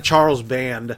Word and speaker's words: Charles Band: A lot of Charles Band. I did say it right. Danielle, I said it right. Charles Band.